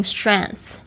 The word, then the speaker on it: strength: grant me the strength， strength 就是指这个 strong 的名词， strength 要特别注意一下它的发音还有拼法哦， s-t-r-e-n-g 不发音 th、它是发这个咬舌头的舌尖的这个气音的声音， strength。